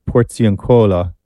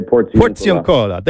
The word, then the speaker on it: Porziuncola: But let's start with tomorrow, because tomorrow at the, I'm not going to say this properly, but at the Porziuncola, lying on the bare ground. – Close. – Was that close? Close. Okay. Yeah. Help me out here. – We would say Porziuncola.